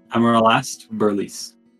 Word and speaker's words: Aramast Berliis: A-R-A-M-A-S-T B-E-R-L-I-I-S Amaral Est Berlis.